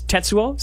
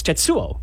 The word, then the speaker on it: Tetsuo: And in the first one, it's Tetsuo, so it's stress on the first syllable. In the second one, it's Tetsuo, which is not.